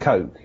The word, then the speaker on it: Koch: That would be K-O-C-H, is it? – Yes, yeah, so he pronounces it Coke himself, right. – But Koch too, if you write it.